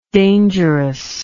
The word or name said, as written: dangerousness